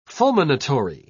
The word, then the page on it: fulminatory: British English